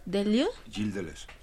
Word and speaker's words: Gilles Deleuze: Gilles Deleuze.